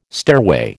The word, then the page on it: stairway: American English